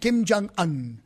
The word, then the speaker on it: Kim Jong-un: Kim Jong-un. – Kim Jong-un.